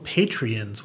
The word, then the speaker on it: Patreon: Is it Patreons? – Sometimes pronounced Patreon. – Okay. – That's how I've heard it sometimes.